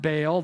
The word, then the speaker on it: Baal: You may hear this pronounced Baal, that's fine, but in Hebrew, probably they pronounce both vowels.